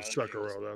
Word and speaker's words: Sekiro: Oh, Sekiro, that's how it's pronounced.